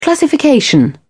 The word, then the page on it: classification: British English